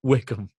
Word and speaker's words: Wycombe: Wycombe.